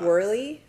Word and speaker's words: Woehrle: So if I say it like this, because this is how all my teachers pronounced it, see if you can do it. Whoa, Hurley. – W-U? – Whoa, Hurley. – W-H? – W-O. – We already did that. – W-O. – Whoa, Hurley. – Hurley, like the brand. – But not like spelled like that. – Okay. – W-O-E-H-R-L-E. It's German.